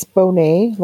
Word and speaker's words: Bonnet: It's Bonnet, like blue bonnet butter.